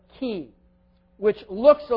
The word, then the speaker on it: chi: It's you know, we write it in English as like a CH, you know, pronounced chi.